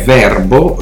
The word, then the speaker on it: VRBO: Altro sito molto importante è Verbo, eh, un nome impronunciabile, lo dico in italiano perché pare che si pronunci così, ma è scritto VRBO eh,